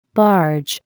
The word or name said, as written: barge